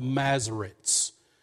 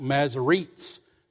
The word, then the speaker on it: Masoretes: Masoretes. I know that looks like Masoretes, but you pronounce it Masoretes.